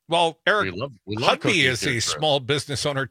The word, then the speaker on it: Hovde: Well, when you talk about the best and brightest from Wisconsin, I'm less clear that either Eric Hudby, and I've learned, I heard his ad last night, it's Hudby. – Hudby, yes. – I was calling him Hudby, but, emphasis on the ho, like sellout, Laguna Beach sellout ho.